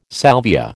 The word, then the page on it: salvia: American English